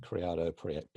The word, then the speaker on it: Criado-Perez: Yes, it's a Spanish surname, rather long. Criado-Perez, I guess, would be the English way of pronouncing it. – Brilliant. Criado-Perez.